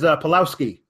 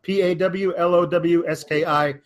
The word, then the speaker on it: Pawlowski: My last name is Pawlowski. Very easy to spell. Pawlowski. Hard to pronounce on first reading, but yeah.